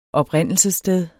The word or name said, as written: oprindelsessted